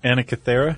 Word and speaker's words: Antikythera: Antikythera.